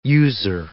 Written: user

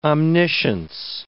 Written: omniscience

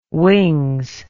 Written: wings

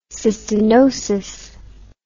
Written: cystinosis

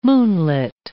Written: moonlit

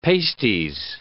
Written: pasties

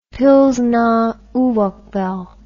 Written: pilsner urquell

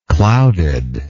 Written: clouded

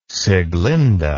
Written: Sieglinde

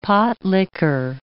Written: pot likker

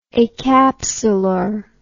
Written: acapsular

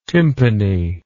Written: tympani

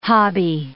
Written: hobby

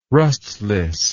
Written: rustless

